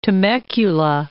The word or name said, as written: Temecula